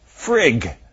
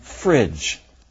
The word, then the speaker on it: fridge: If we took out the D in fridge, the resulting word would easily be mispronounced frig. The addition of the D helped produce the J sound in fridge.